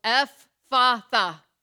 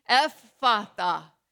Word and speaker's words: Ephphatha: It's really, what's tricky about it is the double consonant. We don't do that in English, right? And it's three syllables, Ephphatha, Ephphatha. Will you try saying it with me? Ephphatha, Ephphatha, Ephphatha, be opened.